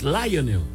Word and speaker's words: Lionel: No es Lionel, es Lionel.